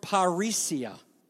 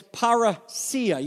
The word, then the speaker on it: parousia: Well, this word, and this is where I want to kind of camp out today, this word that is translated openly is the Greek word parisia. Now, I just said it wrong, and I'll tell you why. Because this is how I learned it in Greek class. But it's parousia. You see the iota at the end, and you see that acute accent?